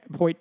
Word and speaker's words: Hoyt: Do you think, like, they're letting people like Hoyt, by the way, if you forget his name, rhymes with exploit.